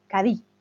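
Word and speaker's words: Cádiz: Y dice exacto, con énfasis en la primera sílaba, pero como los andaluces se comen la S y la Z, al final dicen Cadí.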